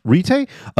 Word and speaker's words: Retay: Retay? R-E-T-A-Y. It sounds exactly like it sounds.